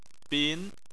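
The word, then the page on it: bin: tone 2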